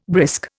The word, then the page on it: brisk: Indian